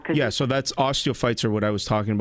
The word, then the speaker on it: osteophytes: Ossites okay. – in my osteophytes.